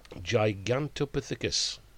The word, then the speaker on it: Gigantopithecus: Gigantopithecus.